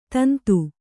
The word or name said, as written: ತುಂದಿ